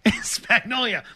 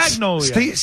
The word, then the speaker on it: Spagnuolo: Spagnuolo. Like Magnolia, Steve, but Spagnuolo. – Steve Spagnuolo.